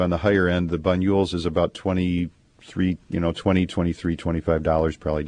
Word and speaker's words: Banyuls: Banyuls. It's spelled B as in boy, A-N-Y-U-L-S.